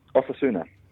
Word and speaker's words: Osasuna: And Osasuna. – Osasuna.